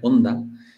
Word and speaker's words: Honda: Vale, entonces tengo que dejar de pronunciar Honda. Es que en español la H la intentamos no pronunciar. – Es moda, ¿verdad? Es moda, mucho, sí. – ¿No? Pero hay que pronunciar en japonés un poco Honda.